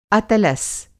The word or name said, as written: अतलस